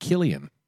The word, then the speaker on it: Cillian: It's pronounced Killian.